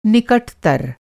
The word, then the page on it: निकटतर: Hindi